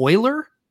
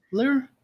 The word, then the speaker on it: Euler: I'm sorry for calling Euler Beats a, a Euler. Is that that? Euler.